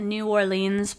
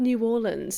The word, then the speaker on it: New Orleans: Ah, I've heard New Orleans, so maybe that's why I was like, where the hell are you? – Yeah, I was doing like a long E, but yeah, we're in New Orleans for the first of 3 days off, which is quite rare on a tour.